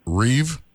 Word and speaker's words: Reeves: Reeves.